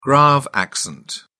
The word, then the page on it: grave accent: British English